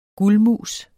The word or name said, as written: guldmus